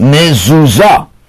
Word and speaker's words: mezuzah: That's a mezuzah, but it's pronounced mezuzah.